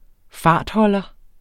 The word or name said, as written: fartholder